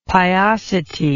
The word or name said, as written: piosity